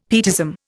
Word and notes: pietism: Indian

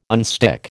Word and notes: unstick: American English

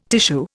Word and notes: tissue: Indian

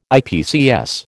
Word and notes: IPCS: American English

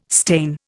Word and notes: stain: Indian

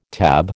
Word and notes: TAB: American English

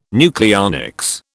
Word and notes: nucleonics: British English

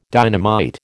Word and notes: dynamite: American English

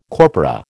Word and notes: corpora: American English

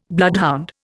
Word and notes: bloodhound: Indian

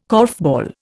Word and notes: korfball: Indian

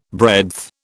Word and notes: breadth: British English

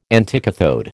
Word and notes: anticathode: American English

